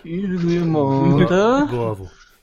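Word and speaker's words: Guillemot: Oh, una bella dichiarazione questa sera di eh, Yves Guillemont, se si, se si, pronuncia così, perché io francese. – Devi stringere, devi stringere, la boccuccia con Yves Guillemont. – Yves Guillemont.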